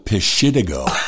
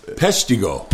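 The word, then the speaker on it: Peshtigo: Hall called it Pishitigo. – Well, how do you say it? – Pestigo. – Pestigo.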